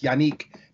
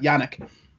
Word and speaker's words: Yannick: That's the correct way of pronouncing that first name, by the way. It's Yannick, not Yannick.